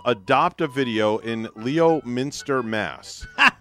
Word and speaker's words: Leominster: Yeah, they say Leo. It's spelled L-E-O. – There's a lot of towns in Massachusetts Leominster. – That are spelled. No, Leominster. – Leominster. – Pretend the O isn't even there.